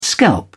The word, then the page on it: scalp: British English